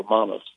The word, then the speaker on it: Romanovs: And it's called The Last Days of the Romanovs, or Romanovs, as the Russians pronounce it.